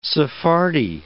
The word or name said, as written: Sephardi